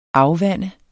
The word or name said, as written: AFVANDEDE